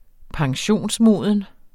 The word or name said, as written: pensionsmoden